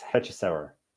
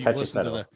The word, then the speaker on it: Hechicero: How do you say his name? – I've not, from other people I've listened to say it, it's Hechicero.